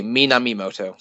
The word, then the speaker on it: Minamimoto: Minamoto or something, I might be getting his name wrong. Okay, I'm looking at a list now. Okay, Minamimoto. So I assume that we get all the names wrong. – I just forgot a syllable the first time.